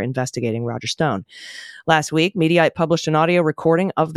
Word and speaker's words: Mediaite: First up, for Mediaite. Not mediate, Mediaite. I've been pronouncing it incorrectly. Thank you, by the way, to everyone who wrote in to school me on how to pronounce that.